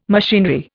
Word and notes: machinery: Indian

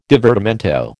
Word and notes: divertimento: American English

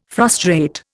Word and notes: frustrate: Indian

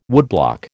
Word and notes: woodblock: American English

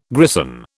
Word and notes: grison: British English

